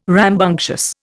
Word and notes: rambunctious: Indian

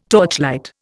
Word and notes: torchlight: Indian

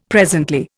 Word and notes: presently: Indian

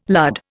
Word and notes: lud: Indian

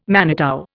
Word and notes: manitou: Indian

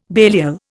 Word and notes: Belial: Indian

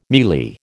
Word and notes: mealy: American English

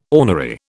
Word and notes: ornery: British English

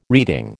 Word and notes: reeding: American English

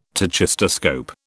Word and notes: tachistoscope: British English

